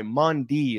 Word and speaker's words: Maundy: Monday, Thursday, Latin, Monday, M-A-U-N-D-Y, Mundi is how it's pronounced.